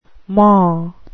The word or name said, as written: maw